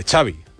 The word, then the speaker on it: Xavi: cómo se pronuncia Xavi Hernández, dice Xavi, like, como chocolate, Xavi, chocolate, Xavi Hernández.